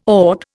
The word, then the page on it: AUT: Indian